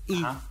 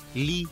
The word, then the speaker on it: Lee: ¿Por qué la, los, nombres o, el, nombre que es Le, L-E-E, es I. O sea, no se dice Li.